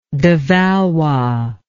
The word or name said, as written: de Valois